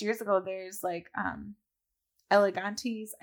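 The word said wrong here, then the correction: Elegantees: I never know if I said that right. It's Elegant Tees, the company.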